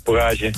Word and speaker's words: Bourrage: Jij bent chef-kok bij Bourrage. Zeg ik dat goed? – Ja. Ja, Bourrage.